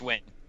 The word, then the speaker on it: Nguyen: It's pronounced Win. – Win? Yeah. No, it's not. – Nguyen. – Yes, it is. – Oh, no. That is Win.